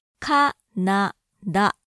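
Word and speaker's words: ka-Na-da (カナダ): The English spelling has three A's, C-A-N-A-D-A. But in North American English, at least, the A's are pronounced differently, ka-na-da. I'll only say the vowels to make the point. I went to a-a-a, Canada, compared to Japanese, Canada. I went to ka-Na-da.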